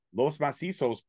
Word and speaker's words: Los Macizos: And Los Machismos, or Los, how do you say it again? – Macizos. – Los Macizos put Anton through the glass with a powerbomb.